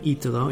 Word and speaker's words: Italo: A young Italian man named Italo, that's how you pronounce his name, Italo, is now confessing saving faith in Jesus Christ.